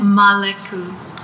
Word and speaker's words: Maleku: How do you say that name again? – Maleku. M-A-L-E-K-U.